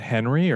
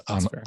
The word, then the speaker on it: Henri: Is that Henry or Henri? – You know, I have to get a French pronunciation into every episode now. So That's fair. – It's Henri.